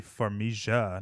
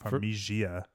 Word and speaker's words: Farmiga: It would be Farmiga, not Farmigia.